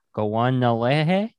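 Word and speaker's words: Kawennáhere: So how you say Devery's name, according to how he sounded out for me, is Gowanalehe? No, the Gowanalehe. Even though it starts with a K.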